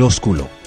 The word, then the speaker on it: ósculo: El arte del ósculo. – Por favor. – El beso perfecto. – Ósculo. – No, no, ósculo. El acento en la O. Ósculo.